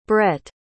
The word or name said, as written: bret